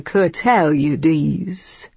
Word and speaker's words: Curtaliades: "Mr. Curtaliades," he said. – I repeated that to myself several times since it was a name I'd never encountered. Emphasis on the tau, I told myself, in a long E.